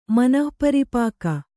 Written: ಮನಃಪರಿಪಾಕ

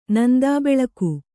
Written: ನಂದಾಬೆಳಕು

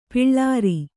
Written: ಪುಲ್ಲೋರಿ

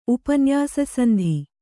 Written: ಉಪನ್ಯಾಸಸಂಧಿ

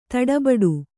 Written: ದಡಬಡ

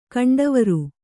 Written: ಕಂಡವರು

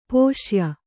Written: ಪುಸ್ಯ

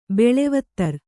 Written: ಬೆಳೆವತ್ತರ್

